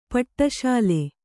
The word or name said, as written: ಪಟ್ಟಶಾಲಿ